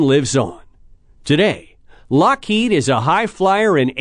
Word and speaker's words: Loughead: The brother's original last name was spelled L-O-U-G-H-E-A-D. So folks would say Loghead or Loafhead. Not too impressive. The correct way to say it is Lockheed.